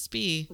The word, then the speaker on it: Spy: It's spelled Spy, pronounced Spee.